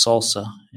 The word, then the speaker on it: SLSA: SLSA, or pronounced SALSA, and you can find that at slsa.dev, and we'll have a link in the show notes.